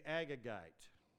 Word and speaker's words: Agagite: Agagite, is that how it's pronounced? Thank you. The Agagite.